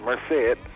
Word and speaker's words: Merced: I have to say it like she said, Merced, in the state of California.